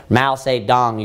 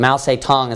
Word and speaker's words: Mao Zedong: Mao Zedong, you can pronounce it. Sometimes it's pronounced in English. But Mao Zedong is how it's pronounced in Chinese, or in China.